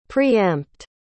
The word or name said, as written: pre-empt